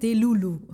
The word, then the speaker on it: Delulu: Delulu. – Delulu. – Ach, so wird man das aussprechen? – Das würde ich sagen, das heißt Delulu.